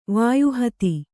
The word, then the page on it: ವಾಯುಹತಿ: vāyu hati